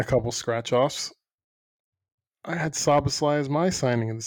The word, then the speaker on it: Szoboszlai: Dominic Szoboszlai. – Szoboszlai, yeah. – It's that easy. – You got it pretty right. Yeah, it's not really that hard. It's the way it looks that's intimidating.